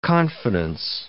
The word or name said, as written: confidence